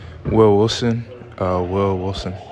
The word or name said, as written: Will Wilson